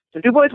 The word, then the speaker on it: Du Bois: And that was, I guess, the way that Mr. Du Bois, that they named the town after, that was the way he pronounced it. So Du Bois, Wyoming.